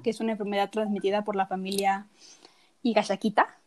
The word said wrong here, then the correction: Higashikata: no, Higashikata, lo dije mal, de generación en generación, que hace que a partir de tu cuerpo comienza a, transformarme, a transformarse en roca.